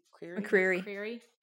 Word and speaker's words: McCreary: So I know that Bear McCreary, is that how you say his name? – McCreary.